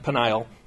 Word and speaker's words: Penuel: So we find that Jeroboam refurbished and fortified two cities, Shechem and Penuel, sometimes pronounced Peniel.